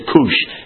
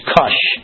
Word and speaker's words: Cush: Cush. That's the proper pronunciation. I always used to say Cush. It's Cush. Cush.